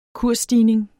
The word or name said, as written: kursstigning